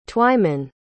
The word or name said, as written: twyman